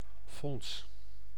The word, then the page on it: caisse: France; [yn kɛs]